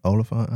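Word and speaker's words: Olyphant: Oliphant.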